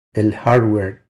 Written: El hardware

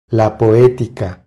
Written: La poetica

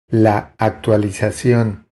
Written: La actualizacion

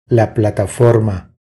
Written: La plataforma

